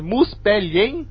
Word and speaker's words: Muspelheim: é Muspelheim.